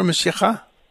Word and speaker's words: Mashiach: Mashiach? – Yeshua and Messiah. – In Assyrian, it's Mashiach or Mashiacha? – Mashiach. Mashiach. – Mashiach, yeah, that means the anointed one.